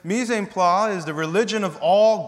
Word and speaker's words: mise en place: I want to draw our attention to a little phrase here, mise en place, but I think it's French and it's pronounced mise en place.